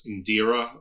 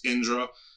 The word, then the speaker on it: Indra: So and Indra's, we probably pronounce it here on when it's anglicized as Indira, or we think it's but it's Indra,